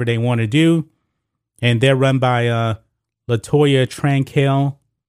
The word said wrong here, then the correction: Cantrell: I believe that's the way you pronounce her uh, her name, Cantrell. That's the way you pronounce her name.